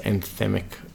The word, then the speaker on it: anthemic: And they're like, it's going to be, like, one of those anthemic Is that how you pronounce it? Anthemic? Anthem? Like an anthem.